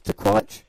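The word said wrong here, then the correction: Tuwaiq: Tuac Trophy.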